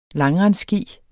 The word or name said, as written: langrendsski